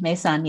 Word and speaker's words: Meisaan: Mason, yes.